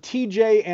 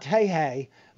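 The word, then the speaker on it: Tejay: The young pitcher, the rookie pitcher for the Cincinnati Reds is named TJ Antone. Not Tehei, like it looks like it would be pronounced, but TJ.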